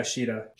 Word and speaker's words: Sheyta: Shieta Synthesis 12. I don't think I said her name right, but yes, that one. S-H-E-Y-T-A. – Yeah. Oh, yeah, Shida. – Shida? Okay.